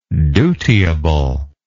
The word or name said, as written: dutiability